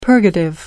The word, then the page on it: purgative: American English